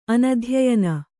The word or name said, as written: ಅನಧ್ಯಯನ